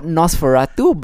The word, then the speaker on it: NOS4A2: But it's called Nosferatu, but it's spelled Nos4A2.